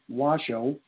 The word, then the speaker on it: Washoe: He was apparently not briefed ahead of time on how to pronounce the Gila and the Washoe rivers, pronounced Gila, H-E-L-U-H, and Washoe, W-A-S-H-O, is the way they got it pronounced, respectively.